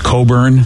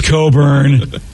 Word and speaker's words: Cockburn: I think it's actually pronounced Coburn for a lot of people. – No, probably. – Yeah. – How is it? – Yeah. – Well, it's not spelled that way. – Yeah. I know it's not spelled that way. I think there was a John Coburn, a musician, but it's spelled like Cockburn, if I'm not mistaken.